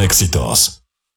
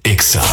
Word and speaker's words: Exa: No se dicen éxitos, se dice Exa.